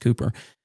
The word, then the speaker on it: Cowper: It looks like Cowper, but it's actually pronounced Cooper, who wrote a number of other wonderful hymns, but just suffered with debilitating depression.